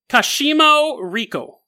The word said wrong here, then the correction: Kashima Riko: Kashimi. Sorry, I did not pronounce that right the first time. Kashima Riko.